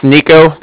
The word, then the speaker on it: Niko: It's Nico, that's N-I-K-O. – N-I-K-O, okay.